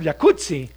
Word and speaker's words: jacuzzi: Entonces deberíamos pronunciarlo jacuzzi. – Exacto. – Como pizza. Como Rampazzo, sí. Como Claudia Rampazzo.